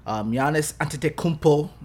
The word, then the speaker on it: Giannis Antetokounmpo: Giannis Antetokounmpo, that's how you say his name, is going to the NBA playoffs.